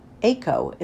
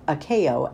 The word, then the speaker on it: Ako: called Des Moines Democratic Iowa Representative Akeo Abdul-Samad Ako instead of Akeo, as he pronounces his name.